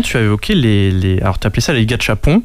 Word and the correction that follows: gachapon: Il me semble que ça se prononce plutôt gachapon.